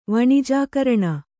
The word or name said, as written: ವಣಿಜಾಕರಣ